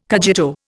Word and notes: cogito: Indian

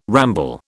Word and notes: ramble: British English

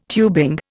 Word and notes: tubing: Indian

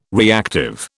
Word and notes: reactive: British English

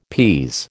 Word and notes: pease: American English

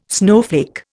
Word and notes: snowflake: Indian

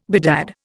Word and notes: bedad: Indian

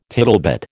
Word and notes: tittlebat: American English

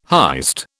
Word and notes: heist: British English

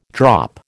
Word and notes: drop: American English

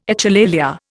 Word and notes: echolalia: Indian

